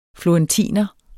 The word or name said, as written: florentiner